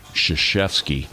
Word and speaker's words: Krzyzewski: Yeah, it starts with a K, and it doesn't sound like a K. – Krzyzewski.